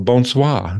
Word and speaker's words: bonsoir: So welcome and bonsoir.